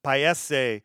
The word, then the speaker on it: paese: Anyway, Miners Paese is a single that they released on June 24th of this year, 2021. And this particular single uh, translates to country. In Italian, it's pronounced paese. Paese.